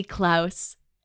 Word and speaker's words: Klaus: Klaus, he says. – No. No, he's asking her to call. No. – The way he says it, it rhymes with house rather than clause. Call me Klaus.